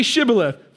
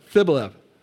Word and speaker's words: Shibboleth: They couldn't make the sh sound. That's how they despised it. They had a speech problem. say Shibboleth, Sibboleth. No. Shibboleth, Sibboleth. No, you're an Ephraimite. For he could not pronounce it right.